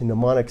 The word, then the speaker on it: mnemonic: stuttering here the mnemonic. See mnemonic so I always see how it's spelled, and so it messes me up how I say it because it's M N right.